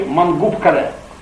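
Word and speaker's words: Мангуп-Кале: Ныне мертвый город на Столовой горе, недалеко от Бахчисарая, в татарском произношении Мангуб-Кале, Мангубская крепость.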